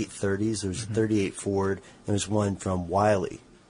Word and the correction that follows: Willys: Am I saying that right? – I think it's Willys.